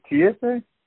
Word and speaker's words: Kiese: Is it Kiese? – I believe so, yes. I'm in the same boat.